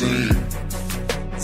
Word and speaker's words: zine: It's pronounced zine.